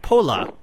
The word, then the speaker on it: Phola: My guest is trumpeter and flugelhornist Hugh Masekela, and his new album, which is pronounced Pola, is out now.